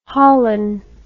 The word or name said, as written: holon